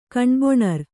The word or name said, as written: ಕಣ್ಬೊಣರ್